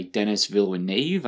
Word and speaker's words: Denis Villeneuve: And then Dune, the latest by Denis Villeneuve.